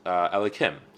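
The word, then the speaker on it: Elokim: It's not pronounced Adai and then Noi. Rather, it's pronounced Elikim.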